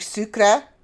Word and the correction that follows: sucre: and it's sucre. Just, you stop at the R. There's no E.